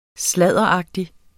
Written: sladderagtig

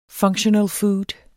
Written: functional food

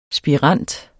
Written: spirant

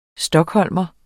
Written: stockholmer

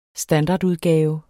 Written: standardudgave

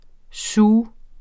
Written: SIOUXEN

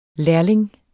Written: lærling

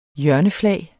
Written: hjørneflag

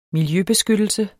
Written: miljøbeskyttelse

